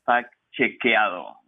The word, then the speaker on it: Factchequeado: You are now a fact checker at a Spanish language fact checking organization, and correct me if I pronounce it incorrectly, Fact Checkuiado. Do I have that right? – Almost right, Bill. Is it Fact Chequeado, like cheque with ado at the end?